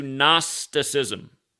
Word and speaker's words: Gnosticism: They're also very much into Gnosticism, a word that starts with the silent G and again, geometry.